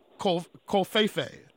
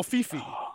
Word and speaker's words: covfefe: Although it did not win Word of the Year, it did win under the category of WTF Word of the Year, Doc Kofefe. – What? – Or Kofifi. – Kofifi. – Or Kofefe, depending on. I'll spell it for you. COVFEFE. Kofefe or Kofifi? – Okay, I don't know.